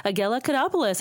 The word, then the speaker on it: Agella Kodopulos: Agella Kodopulos.